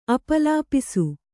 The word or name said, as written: ಅಪಲಾಪಿಸು